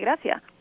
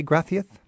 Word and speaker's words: gracias: Gracias, do you both say gracias? – Gracias. Gracias. That C is actually a TH sound, but we keep the S phonetically as the S sound, you know.